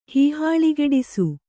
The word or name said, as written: ಹೀಹಾಳಿಗೆಡಿಸು